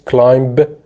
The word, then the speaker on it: climb: climb climb climb دقت کنید climb غلط به تلفظ نمیشه. climb یه اسم معروف هم ازش داره. climber کوهنورد. climber climber باز نمیگیم اونجا.